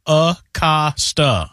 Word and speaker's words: Acosta: I am on the FC Cincinnati website, and the official pronunciation is Luciano Acosta.